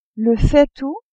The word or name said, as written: faitout